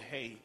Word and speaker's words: He: It's the fifth letter of the Hebrew alphabet is called, it's what we'd say H, but it's pronounced the He.